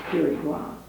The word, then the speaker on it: Quirigua: It begins with a Q, strange spelling. pronounced Pirigua.